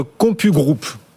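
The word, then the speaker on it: CompuGroup: Vous nous faites découvrir aujourd'hui le titre, euh, j'espère bien le prononcer, le titre Compu Group. – Tout à fait, exactement, c'est bien prononcé.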